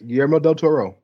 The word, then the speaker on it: Guillermo del Toro: Am I pronouncing him right? – Guillermo, yes. Guillermo del Toro.